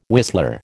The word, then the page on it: whistler: American English